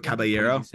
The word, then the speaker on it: Caballero: He's just kind of he's a little bit like the figure waving for Jose Cabrera or Calabero. How do you say his last name? – Caballero. Jose Caballero. Yeah.